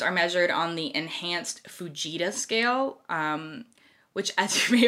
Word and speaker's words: Fujita: It also looks a lot like fajita, but ooh, then I was looking up how you pronounce it, and the internet insisted that it's Fujita.